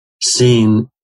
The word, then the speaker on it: sin: Sin in the later reading traditions is pronounced like samich. It's pronounced like a regular S.